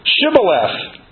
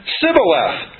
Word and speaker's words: Shibboleth: Then said they unto him, say now, Shibboleth. And he said, Sibboleth. For he could not frame to pronounce it right.